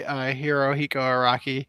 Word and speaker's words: Hirohiko Araki: Bao is also by Hirohiko Araki. Or whatever his name is. – You say it correctly.